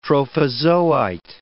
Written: trophozoite

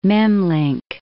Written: Memlinc

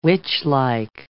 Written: witchlike

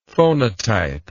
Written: phonotype

